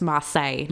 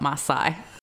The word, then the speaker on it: Marseille: Okay, I've just looked up how it's pronounced and it's Marseille, not Marseille.